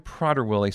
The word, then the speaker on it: Prader-Willi: And I've been pronouncing it wrong throughout the show already. Prader, it's actually Prader-Willi syndrome.